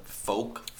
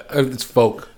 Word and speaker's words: Foch: The actress' name was Nina, how would you say her, her folk? Foch? F O C H? Uh, it's folk.